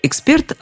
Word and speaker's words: эксперт: Эксперт от латинского «expertus» – «опытный», а «expertus» от глагола «experio experior» – «пробую, испытываю». В общем, экспертное заключение таково. Слова «эксперт», «экспертный», произносится только так и никак иначе.